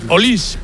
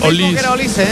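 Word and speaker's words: Olise: Olis, porque en francés todas las seis finales que no van acentuadas pronuncian Olis.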